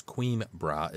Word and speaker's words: Coimbra: So I did notice that it was Queenbra is the way this one's pronounced because it's spelled C-O-I-M-B-R-A.